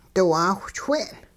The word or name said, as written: diwaawhchwing'